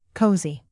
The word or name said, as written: cozy